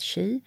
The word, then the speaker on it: qi: Så QI och uttalat chi, det är livsenergin.